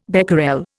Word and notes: becquerel: Indian

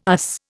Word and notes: arse: Indian

